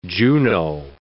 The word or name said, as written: Juno